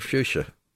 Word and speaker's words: fuchsia: So fuchsia, as everyone calls it, those lovely hanging basket plants that everybody wants, should be pronounced Fuchsia because it was named after German botanist Leonard Fuchs.